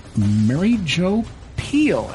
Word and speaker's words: Mary Jo Pehl: One of them is Mary Jo Pehl, and I hope I'm pronouncing that correctly.